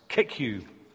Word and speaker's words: CICCU: He's talking about the Cambridge Intercollegiate Christian Union, always known by its initials and pronounced CICCU.